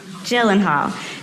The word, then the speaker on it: Gyllenhaal: Gyllenhaal.